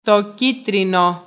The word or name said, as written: το κίτρινο